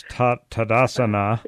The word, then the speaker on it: Tadasana: Okay, first of all, tell us how to pronounce Tadasana.